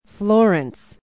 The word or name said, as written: Florence